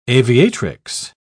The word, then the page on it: aviatrix: British English